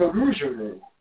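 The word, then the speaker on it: rougarou: rougerou, also spelled rouge-garou, rougerou, and rougerou. – And it sounds so cool when you roll your R, which I can't do. – Rougerou? – There you go.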